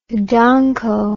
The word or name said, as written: donko